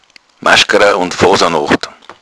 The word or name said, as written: foosanocht